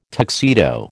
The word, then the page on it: tuxedo: American English